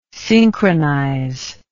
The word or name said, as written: synchronize